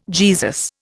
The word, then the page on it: Jesus: Indian